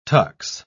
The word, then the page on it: tux: British English